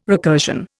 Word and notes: recursion: Indian

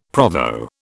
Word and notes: Provo: British English

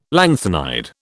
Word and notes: lanthanide: British English

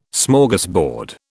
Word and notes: Smorgasbord: British English

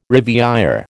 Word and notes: riviÊre: American English

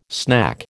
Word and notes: snack: American English

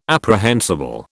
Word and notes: apprehensible: British English

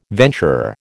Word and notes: venturer: American English

